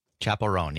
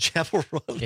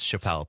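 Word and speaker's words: Chappell Roan: Oh, it's Chapel Roan. – It is. I wouldn't have known if I had not heard it. – Is that how you pronounce either. – It? All right. – Yeah. – All right. – I would have also guessed Chappelle based on how it's spelled.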